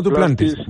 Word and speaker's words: Duplantis: Pues eh, me encantaría, lo haré con sumo placer y ya que estamos, eh, celebro la proeza de Mondo, de Herman Mondo no sé cómo lo pronuncia. Duplantis. – Duplantis. – no, Duplantis. Mondo Duplantis.